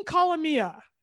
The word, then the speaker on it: Calamia: Creating Luminous Spaces by Maureen Colomia.